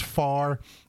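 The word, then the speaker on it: Pharr: So I'm in Far, Texas. It's pronounced Far. It's spelt Pahar.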